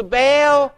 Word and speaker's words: Baal: Hence, sacrifices to Baal, B-A-A-L. Why that's pronounced Baal, I don't know, but sacrifices to Baal were strictly forbidden in Israel.